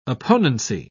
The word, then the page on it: opponency: British English